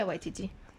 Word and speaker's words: Waititi: For those of you that have not seen this movie yet, Jojo Rabbit is a movie by Taika Waititi. I can never say his name. – Is it? – Yeah, that's right. – Yeah, Waititi. – Waititi.